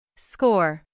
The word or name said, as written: score